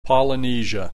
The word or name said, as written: Polynesia